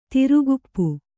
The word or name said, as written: ಥೇರಕಪ್ಪ